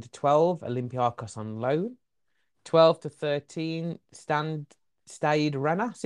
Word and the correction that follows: Stade de Rennes: is it? I think that's just pronounced on loan. – Stade de Rennes, that. – Yes, Stade de Rennes, yeah.